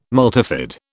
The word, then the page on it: multifid: British English